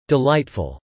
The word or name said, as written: delightful